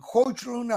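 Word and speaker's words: Højlund: No sé si, si lo dije bien. Hoechlund había hecho dos goles para... Sí, lo hizo bien, solo que sí.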